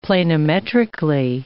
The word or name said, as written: planimetrically